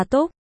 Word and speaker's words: Túc: Su, Hán Việt đọc là Túc.